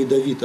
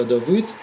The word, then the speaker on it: Давыд: В старом орфографии мы произносим не «Давид», а «Давыд».